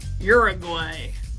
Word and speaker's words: Uruguay: Uruguay. I had to say it like an American. – Yeah. Can you say it as a non-American? – Well, it doesn't start with a your sound.